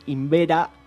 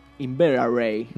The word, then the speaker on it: Inveraray: La cárcel de Invera, Inveraray.